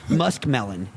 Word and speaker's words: muskmelon: I was just saying it wrong for my whole life. It's muskmelon. – Muskmelon? – Yes, musk. – Like a deer piss. – Yes, yes. – Oh. – M-U-S-K. Muskmelon.